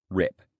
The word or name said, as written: rip